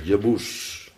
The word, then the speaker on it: Jebus: En zo spreken de Joden het ook uit. Jeboes. Jeboes.